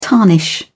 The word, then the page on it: tarnish: British English